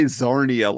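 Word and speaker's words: Czarnia: And Lobo's is it Kazarnia? That's how I always pronounce it. – I always just say Zarnia, like the C is silent.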